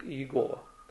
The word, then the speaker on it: Иегова: А будем говорить о почитании Божьего имени, четырехбуквенного ветхозаветного Божьего имени, которое свидетели Иеговы произносят как Иегова.